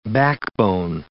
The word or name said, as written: backbone